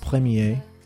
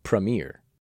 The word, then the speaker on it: premier: First of all, there are several accepted pronunciations of these words. – Premier. – Premier. – Premier. – Premier. – Premier. – Premier. – All of these are correct pronunciations,